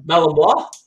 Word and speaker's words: Malinois: So you're saying the Belgian Malinois. Did I say that right? – Yep, Malinois.